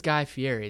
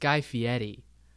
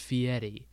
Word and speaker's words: Fieri: Everyone says Guy Fieri. It's Guy Fieri. In his episode of Hot Ones, Sean Evans asked him how he pronounced it, and he's like, it's pronounced Fieri.